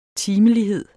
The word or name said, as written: timelighed